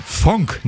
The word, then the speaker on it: Phonk: Funk, nicht Funk. Funk. P-H-O-N-K.